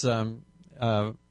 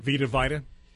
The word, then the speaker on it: Vita: It's Vita Sackville West, or Vita Sackville West, however you want to pronounce her first name. – Vita.